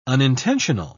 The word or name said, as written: unintentional